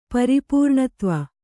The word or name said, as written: ಪರಿಪೂರ್ಣತ್ವ